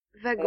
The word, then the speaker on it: wagon: se prononce comment en France Vagon. Euh, wagon. – Avec un V, pas un W en fait.